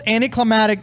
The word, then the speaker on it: anticlimactic: It's the most anticlimactic video game I've ever played in my life. Yeah. – Climactic? – Climactic? Is that how you say it? – Anticlimactic.